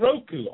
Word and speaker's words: Roku: It's called Roku. R-O-K-U.